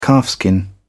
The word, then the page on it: calfskin: British English